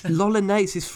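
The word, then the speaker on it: L'Olonnais: Lolonnais. His name has got various versions. I've gone for Francis Lolonnais because it's the easiest to pronounce, and it sounds like mayonnaise.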